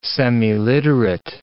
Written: semiliterate

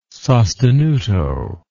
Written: sostenuto

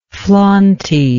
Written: flauntiness

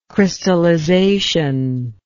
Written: crystallization

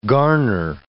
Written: garner

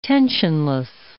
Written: tensionless

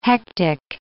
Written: hectic

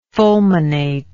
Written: fulminatory

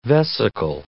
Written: vesical

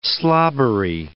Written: slobbery

